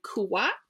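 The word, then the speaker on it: Kuyatt: So we will be interviewing the author of Good Different, and her name is Meg Eden Kuwat.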